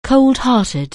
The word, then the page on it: cold-hearted: anglais